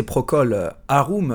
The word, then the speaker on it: Procol Harum: Et puis pour la petite histoire, si j'ai eu du mal peut-être à prononcer Procol Harum, euh, c'est tout simplement puisque ce n'est pas euh, un nom euh, anglais ni anglophone, en fait ça vient tout simplement du latin.